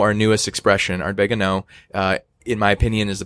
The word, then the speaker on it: An Oa: Spell that. A N mm-hmm. uh, O A. So two words. – So it's like a Scotsman saying, I know.